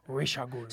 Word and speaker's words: Ra's al Ghul: She is the daughter of Ra's al Ghul, sometimes referred to as Ra's al Ghul. – It's actually the correct way to pronounce it is Ra's al Ghul.